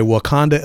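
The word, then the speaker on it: Wauconda: it's spelled differently, but it's pronounced the same way. Wakanda, Illinois.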